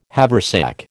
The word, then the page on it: haversack: American English